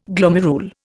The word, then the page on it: glomerule: Indian